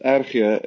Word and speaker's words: RG: Oh, yeah, it's pronounced Erge in Afrikaans.